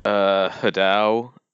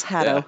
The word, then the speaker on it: Hatto: Hadao. They butcher it. – Yeah, yeah. – And like needlessly butcher. I'm like, it's just Hatto. – It's exactly the way it looks. Hatto. Yeah. Yeah.